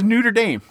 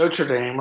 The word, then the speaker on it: Notre Dame: There are two acceptable pronunciations, and Neuter Dame is not one of them. So that is my. – But I'd say Notre Dame, right? – Notre Dame, yeah, that's how I would say it.